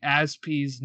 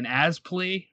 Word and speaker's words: Azpi: And apparently the whole reason what they were joking about is Tuchel was trying to get a hold of Azpilicueta, and he kept saying Azpi's name wrong. He was saying Azpli or something, so Pulisic went over there and said it's Azpi.